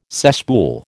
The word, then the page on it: cesspool: American English